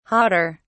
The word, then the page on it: jader: British English